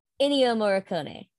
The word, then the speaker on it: Ennio Morricone: Gore White was by, I will literally get this name wrong, it's Ennio Morricone, wrong, but that's who did the score. – Ennio Morricone.